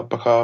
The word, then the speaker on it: Buka: B-U-K-A. It is pronounced like the wrestler, Booker, but just spelled differently.